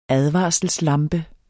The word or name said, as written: advarselslampe